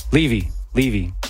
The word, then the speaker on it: Levy: Levy. I asked him in person. He said it's Levy. Been saying it wrong this whole time.